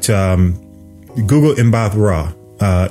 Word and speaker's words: Gugu Mbatha-Raw: Gugu was the actress, Gugu Imra. I always say her name wrong. But Gugu Mbatha-Raw is the actress in that.